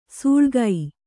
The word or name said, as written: ಸೂಳ್ಗೈ